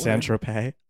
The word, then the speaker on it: Saint-Tropez: Wonder if that Saint-Tropez... Is that the right word? – Saint-Tropez. – Oh. – You put the emphasis on the wrong syllable.